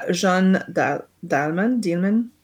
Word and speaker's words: Jeanne Dielman: And the new champion, Chantal Ackermans, Jeanne Dielman, 23 Quai du Commerce, 1080 Bruxelles.